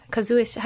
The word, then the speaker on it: casuistry: The Anglican Church shuts him down, and he retreats out of Oxford because he is accused of having a deceitful intention of casuistry. If that's a way, is that how you pronounce it, Father? Casuistry.